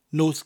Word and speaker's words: Nos: First word is Nos, night, spelled N-O-S, Nos. So you were just saying the word no with a s sound at the end of it. Nos. Then we have Galan, spelled G-A-L-A-N, pretty much pronounced as you spell it.